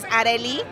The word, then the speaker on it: Areli: Like, my name is Areli. – But what's like one of the worst pronunciations you've heard? – Orally. Oh, shit. The Irish can't say my name for O'Reilly? – Shit. O'Reilly. In Ireland, I'm O'Reilly.